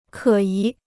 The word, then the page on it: 可疑: kě yí